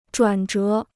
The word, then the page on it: 转折: zhuǎn zhé